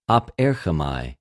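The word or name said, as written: ἀπέρχομαι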